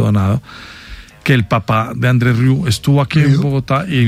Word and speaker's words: André Rieu: Fuera del micrófono, usted me contó una anécdota muy simpática del señor André Rieu.